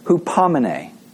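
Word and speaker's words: hupomene (ὑπομονή): And I always look at how you say it, hupomene, right? Hupomene. That's this word, the Greek word for endurance.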